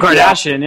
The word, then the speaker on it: Kardashian: Kardashian? You know, you don't try to say, you know? – Kim Kardashian, yeah.